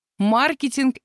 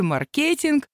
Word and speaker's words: маркетинг: На этом мощном английском фоне вечный спор «маркетинг» или «маркетинг» выглядит детским лепетом на лужайке. Кстати, в словарях зафиксированы оба ударения как нормативные. Можно говорить, кому как нравится.